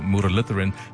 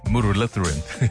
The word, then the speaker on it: Muralitharan: In fact, when I first saw the surname, Muralitharan, I struggled to pronounce it myself, being of South Indian descent, even though he was Sri Lankan, but it's kind of close in terms of pronunciation.